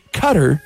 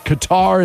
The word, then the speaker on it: Qatar: The country Cutter has the world's highest gender ratio. A lot of people pronounce it Qatar, and that's wrong. – Oh, yeah. That's what you're saying. – Q-A-T-A-R.